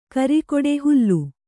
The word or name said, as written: ಕರಿಕೊಡೆ ಹುಲ್ಲು